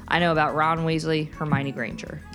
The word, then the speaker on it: Hermione: It's Hermione.